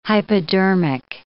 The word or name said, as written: hypodermic